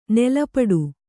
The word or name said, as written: ನೀಲಪಟ